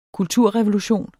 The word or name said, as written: kulturrevolution